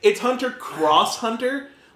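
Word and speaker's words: Hunter × Hunter: No proper way to say it. – Okay. – Like, it's there just to fuck with people. – Okay. There's that. – It's technically Hunter cross Hunter.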